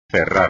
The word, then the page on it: cerrar: espagnol